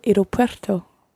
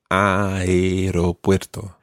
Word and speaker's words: aeropuerto: Voy al aeropuerto. – Yeah, almost right. That aeropuerto, you pronounce the A and the E. The aeropuerto.